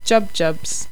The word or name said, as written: jubjubs